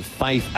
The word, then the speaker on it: Fyffe: Now faces Fife an inning later with a man on. Sermonelli not leading deep. The pitch fouled into the screen by Fife. Fife is spelled F-Y-F-F-E.